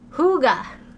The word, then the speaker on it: hygge: If I wrote on a piece of paper the word H-Y-G-G-E, how would you pronounce it? – H-Y-G-G-E? – Yeah. – Hygge. Hygge. Hygge. Hygge. Like my cat. Is that how it is? Hygge? – No. – What is it? – Hooga. – What? – H-O-O-G-A-H is how they spell it out.